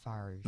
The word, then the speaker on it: Farage: Spelled like Farage or Farage. – Yeah. – Pronounced Farage.